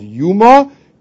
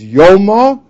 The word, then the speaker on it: Yoma: And we'll just begin the new Mesechta, which is normally called Yuma, but the correct way to pronounce it is Yoma.